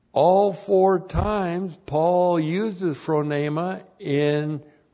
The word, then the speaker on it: phronema: In verse 6, the key word in verse 6 is the word phronema. Phronema, P-H-R-O-N, long E-M-A, phronema.